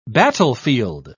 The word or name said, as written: battlefield